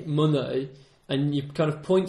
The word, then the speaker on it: oeil: You say mon oeil. So it sounds just like I. – Mon oeil.